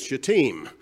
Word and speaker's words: shittim: As Betty told me coming in today, she learned that shittim wood is shittim. Shittim wood is the Hebrew pronunciation of that.